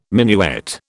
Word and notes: minuet: British English

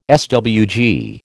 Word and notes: SWG: American English

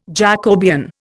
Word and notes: Jacobean: Indian

